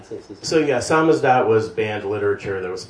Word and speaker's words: samizdat: Samizdat, is that how you pronounce it? – Samizdat, yeah.